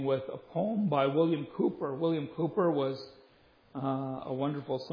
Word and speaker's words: Cowper: It's pronounced Cowper, it's spelled C-O-W-P-E-R, when you see it in your hymnal.